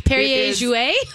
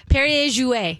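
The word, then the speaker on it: Perrier-Jouët: So we brought out the Pierre Jouet Grand Brut. – Okay. Perrier Jouet? – Thank you.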